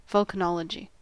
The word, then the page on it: vulcanology: American English